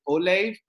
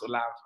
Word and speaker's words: Olaf: I mean, I do have a pet peeve, like Americans who say Olave. I mean, I'll fight you. Yeah. But because Olive just sounds really ugly.